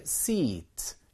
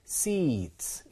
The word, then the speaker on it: seat: 자, 앞쪽에 저 sit는 짧게 발음해요. sit. 뒤쪽에 seat. 이것은 장음입니다. 그래서 길게 발음해서 seat.